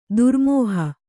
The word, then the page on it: ದರಮಹಾ: daramahā